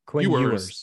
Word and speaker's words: Quinn Ewers: Quinn Ewers.